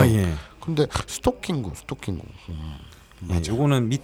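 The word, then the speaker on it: ストッキング: 스타킹구가 아니라 스토킹구구나. 예, 스토킹구. 그럼 스토킹구하고 스토킹구하고 같네? 어, 아니죠. 스토킹은 스토킹구. 토가 장음입니다. 스토킹구.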